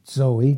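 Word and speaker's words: zoe (ζωή): Now, so again, eternal life is the Greek word zoe, Z-O-E, long E, and zoe, it's pronounced that particular way because the Greek, they pronounce the Z's like we do in our language with a D and a Z before it. So it's almost like zoe, you pronounce it this way.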